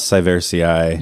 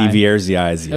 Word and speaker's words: sieversii: 6.8 golden poems of honor for Malus Syversii. – Yes. – The Kazakhstani apple. – Were you saying a moment ago that I add several syllables in yes. – there? – Yes. Yeah. – Syversii.